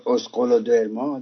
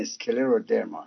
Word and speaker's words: اسکلرودرما: خب این اگه تلفظش بگید اسکولر درما درست هست، ولی این اسکلر و درما هست.